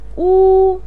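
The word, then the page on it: wu: tone 1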